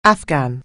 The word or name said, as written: Afghan